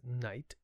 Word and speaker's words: Knight: So my last name is Knight, K-N-I-G-H-T, which I spell it because to this day, if I just say my name to a new person, they're like, N-I-G-H-T.